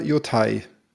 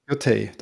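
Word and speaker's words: Yōtei: Ghost of, wie spricht man es denn? Yotei oder Yotai? – Yotei.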